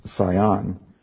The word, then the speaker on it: Sin: I was actually looking at this word in one translation that's spelled Z-I-N, and this one it's S-I-N. It's actually pronounced, from the reference I looked at, as Sion. And it looks like it would be when you look at it compared to Sinai.